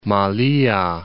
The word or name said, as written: Malea, Cape